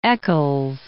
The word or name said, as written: Eccles